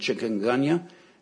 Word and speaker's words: chikungunya: There, I've read several arguments about how you pronounce this word, but I'm going to say it with an English American. Way of saying it, chikungunya. There's a more fancier way to say it, but I couldn't master it.